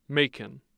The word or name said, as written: Macon